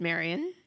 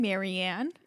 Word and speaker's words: Marian: Am I saying her name wrong? – It's Marian. – Marian. I keep saying Marianne.